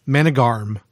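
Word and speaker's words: Månegarm: Manigarm. Am I saying it correctly? – Yeah.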